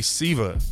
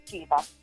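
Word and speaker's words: CIVA: So you are the executive director of Christians in Visual Arts, AKA SIVA, if I'm saying that correctly. – It is. Unless you're Italian, then it's Chiva.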